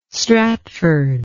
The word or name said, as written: stratford